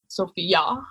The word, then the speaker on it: Sofia: Is it like the girl's name or is it Sofia? – Yeah, I've been told that it's YA at the end, so Sofia.